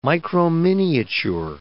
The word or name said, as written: microminiature